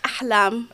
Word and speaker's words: Aklan: It's Aklan. It's the hat. It's an Arabic name.